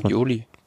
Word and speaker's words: Bagioli: Andrea Bagi Bagioli, spricht man das so aus wahrscheinlich? – Bagioli.